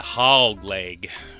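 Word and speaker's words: Hawg Leg: You have to say it like that, Hog Leg. It's spelled H-A-W-G.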